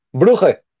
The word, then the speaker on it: Brugge: Brugge.